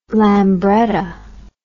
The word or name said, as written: lambretta